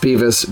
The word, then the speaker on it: Behvis: And I think it's pronounced Beavis, B E H V I S.